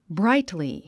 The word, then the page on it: brightly: bráitli; ブライトリィ